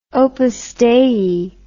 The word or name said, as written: Opus Dei